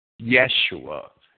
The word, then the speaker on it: Yeshua: Yeshua is the way the Jews like to say it and spell it in English, Y-E-S-H-U-A.